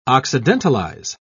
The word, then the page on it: occidentalize: British English